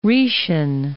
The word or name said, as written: Rhaetian